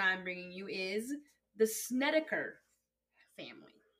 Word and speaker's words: Snedeker: Yes, Snedeker.